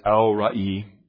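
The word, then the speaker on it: El Roi: In the English, we would say, the name being used here, the way we would say it is El Roy. E-L, space, R-O-I. In the Hebrew, it's not pronounced that way. In the Hebrew, it's El-Ra'i, but either way, that's the name being used here.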